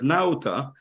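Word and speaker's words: Nauta: Юр, значит, по-русски я проверил, они переводят его как Наута. По-английски, если кому-то интересно, вы услышите, его произносят как Нота. А читается, наверное, по-английски нота все-таки, из того, что я слышал.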